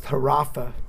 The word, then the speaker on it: Orpah: Her name was Orpah, pronounced Harapha in the Hebrew.